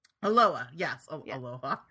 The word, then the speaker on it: Aloha: Yeah, no, it's spelled Aloha, but you just don't pronounce the H for some reason.